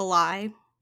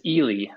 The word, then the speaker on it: Ely: Um, I know this is going to be wrong, but is it like a lie? That's just. – Nope. – of course not. I knew that. How do you pronounce it? – It's Ely, Minnesota.